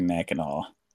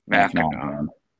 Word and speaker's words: Mackinac: We all say it. We say Mackinac. – Mackinac. – Yeah. Sea is silent, I guess.